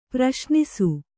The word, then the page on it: ಪ್ರಶ್ನಿಸು: praśnisu